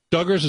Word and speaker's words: Duggars: Duggars, is that how it's pronounced? – Yes.